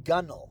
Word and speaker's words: gunwale: Like there's this word gunwale, and it's pronounced gunnel, even though it's spelled gunwale.